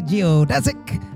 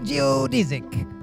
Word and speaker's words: geodesic: In fact, both pronunciations are acceptable. You say geodesic, I say geodesic.